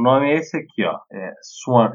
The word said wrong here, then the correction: Swarm: Eu não sei como pronuncia. também você consegue ajudar? – S-W-A-R-M. – Swarm.